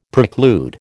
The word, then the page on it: preclude: American English